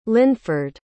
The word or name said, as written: lynford